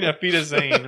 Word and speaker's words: Auf Wiedersehen: I think you mean Auf Wiedersehen. It means goodbye.